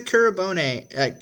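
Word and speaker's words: Kurobane: we're talking about Charlotte now, and Yusa Kuribone, Kuribane. Damn, I knew I'd butcher it. – You had it right the first time.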